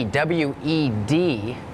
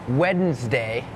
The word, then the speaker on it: Wednesday: ใช่ไหมคะนั่นก็คือตัวอักษรที่ไม่ออกเสียงแต่อย่างคำนี้ค่ะเป็นคำหนึ่งที่มี silent letters Wednesday has that D W E D it's not wed Wednesday Wednesday D is silent